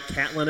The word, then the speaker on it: Catlin: Catlin, am I saying it right? Catlin, Illinois? – It is, yeah.